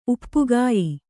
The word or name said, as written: ಉಪ್ಪುಗಾಯಿ